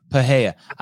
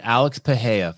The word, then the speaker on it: Pereira: I would argue that Pereira's, how do you pronounce his name? – Paheya. – I would argue that Alex Paheya, 50 minutes in the show, I finally got his name right.